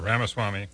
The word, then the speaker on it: Ramaswamy: Vivek Ramaswamy, is that how you say it? – Yeah, Ramaswamy.